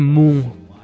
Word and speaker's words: Munch: Pero hay algo bien peculiar, porque algo estaba hablando con el pana Fran de El Grito de Munch, que El Grito de Moon, así es que se pronuncia.